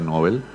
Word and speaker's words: Nobel: Él no quería que se diera Nobel, siempre nos retaba, porque dice que la pronunciación correcta en español es premio Nobel.